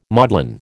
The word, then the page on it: maudlin: American English